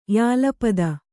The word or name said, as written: ಯಾಲಪದ